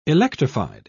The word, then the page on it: electrified: British English